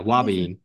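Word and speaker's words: ouabain: Uabane, or how do you say it? – Yeah. Wabane. – Wabane.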